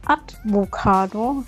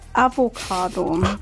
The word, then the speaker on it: Avocado: Ich sage immer Advocado statt Avocado.